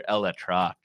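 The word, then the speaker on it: ElAttrache: I worry about Skenes becoming familiar with Dr. Eletrach. Probably butchered that pronunciation. – That's actually pretty close. Pretty darn close. I'll give you credit on that one.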